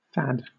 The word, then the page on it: fad: British English